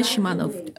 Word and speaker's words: Shamanov: Yes, I'm Eugenia Shamanov.